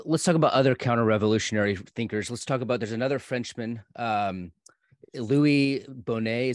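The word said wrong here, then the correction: Bonald: Is that how you say his name? – Bonal. Yeah.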